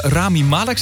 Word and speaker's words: Rami Malek: Die stem die we horen, dat is Rami Malek, zeg ik het goed dan? – Ja, klopt inderdaad.